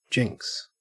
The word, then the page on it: jinx: Australian English